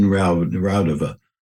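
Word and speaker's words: Raudive: And I wanted to ask you about Konstantin Rodev. Is that how you say his name? – Rodeva. Konstantin Rodeva.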